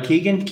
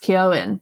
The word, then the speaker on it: Keoghan: Well, you get to see Barry Keegan. Keegan. – I think that it's actually meant to be pronounced Keoghan, but even Keoghan. He says Keegan, and he's like, oh, I'm pronouncing it wrong.